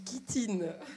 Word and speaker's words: chitine: Alors la chitine, c'est l'exosquelette des insectes qui fait en chitine. Alors, j'ai qui me corrige et qui me dit... – On dit chitine. – Voilà, on dit chitine parce que ça s'écrit CH et moi je dis chitine.